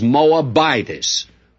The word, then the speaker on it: Moabitess: Now, before somebody writes me a letter to tell me I'm mispronouncing the word Moabitess, I will say that I do know that the correct pronunciation is Moabitess, but I don't like that pronunciation, and when I don't like it, I change it my way, and I hope you'll accept it. I think the Moabitess sounds much better, and so we'll go along with that if you don't mind.